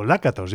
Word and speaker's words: Lakatos: como es Béla Lakatos, o Lakatos, yo creo que se pronuncia más bien con el acento en la primera, como suele ser en las palabras en húngaro, así que me disculpáis por la pronunciación anterior.